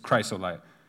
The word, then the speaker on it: chrysolite: It's chrysolite. I know on some of these. I wrote it out in the I actually put the pronunciation like cry, so...